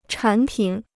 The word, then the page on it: 产品: chǎn pǐn